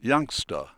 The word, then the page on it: youngster: British English